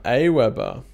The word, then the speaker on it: AWeber: So you are from AWeber. For people who don't, am I saying that right? I've always called it AWeber. Is that how you guys say it? – That is. You've got it.